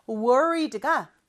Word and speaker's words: worried: Worried가 걱정하는 이라는 뜻이거든요. 발음은 worried. 이렇게 발음하는데 중간에 R 발음할 때는 혀가 어디에도 닿으면 안 돼요. 혀의 양쪽만 긴장을 시켜준다. 이렇게 생각하면서 발음을 해볼게요.